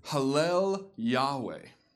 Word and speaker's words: Hallel Yahweh: In Hebrew, when you pronounce this exact phrase, it says, Hallel Yahweh, where we get the concept of Hallel Psalms, meaning praise.